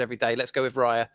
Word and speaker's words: Raya: The Brentford goalkeeper, how would you pronounce his name? – Oh, I'd love to know it. – The number one goalkeeper. David Raya, yeah? – Oh, yeah. Raya. – David Raya. – Let's go with Raya.